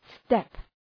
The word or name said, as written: step